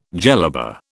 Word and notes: jellaba: British English

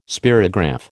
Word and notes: spirograph: American English

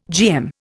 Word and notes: GM: Indian

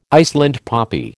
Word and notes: Iceland poppy: American English

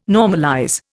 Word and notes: normalize: Indian

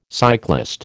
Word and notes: cyclist: American English

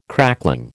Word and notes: crackling: American English

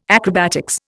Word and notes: acrobatics: Indian

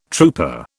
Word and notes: trouper: British English

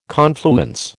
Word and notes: confluence: American English

